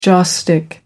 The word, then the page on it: joss stick: American English